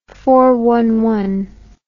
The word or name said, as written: four old cat